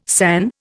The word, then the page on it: san: Indian